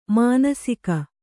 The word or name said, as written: ಮಾನಸಿಕ